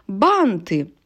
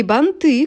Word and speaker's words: банты: Банты. Не банты, как мы могли подумать, а банты, бант, банты, торт, торты, лифт, лифты.